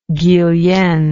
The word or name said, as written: Guillen